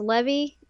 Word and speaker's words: Levi: It's spelled Levi, but it's pronounced Levi.